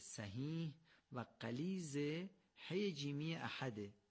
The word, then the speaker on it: احد: ما در فارسی میگیم احد، اما در نماز باید بگیم احد. باید حی رو یه مقدار غلیظ از گلو بگیم. اگر حی رو یه مقدار غلیظ از ته گلو بگیم، خود به خود درست تلفظ میشه. بنابراین چی بگیم؟ احد یا احد؟ قطعا دومی درسته.